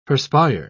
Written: perspire

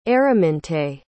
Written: Araminte

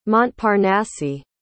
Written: montparnasse

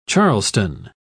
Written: charleston

